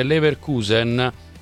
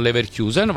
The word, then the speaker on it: Leverkusen: Scusami se eccepisco, ma ho vissuto molti anni a Leverkusen, si pronuncia semplicemente Leverkusen, non Leverkusen, va benissimo, allora accetto la tua lettura, Leverkusen senza Kusen, senza la I.